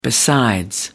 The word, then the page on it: besides: American English